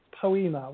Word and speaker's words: Poema: Yeah, my podcast, it's called Poema, which is, it's spelt poem and then an A on the end.